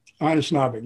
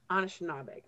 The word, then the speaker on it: Anishnaabeg: Anishnaabeg, yeah. – Anishnaabeg.